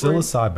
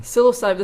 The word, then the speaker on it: psilocybin: I actually don't know how to pronounce this Psilocybin. – Word. Psilocybin.